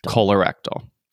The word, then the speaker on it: Colorectal: Colorectal. I did a whole like Google pronunciation. Yeah. – Colorectal. – I want to say collar, but it's the colon.